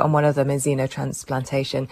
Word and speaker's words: xenotransplantation: And you're going to tell us a little bit more about xenotransplantation. Is that the correct pronunciation? – Yeah, very good. Xenotransplantation.